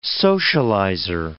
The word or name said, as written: socializer